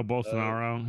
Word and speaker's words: Bolsonaro: Borsellino. – Oh, Bolsonaro.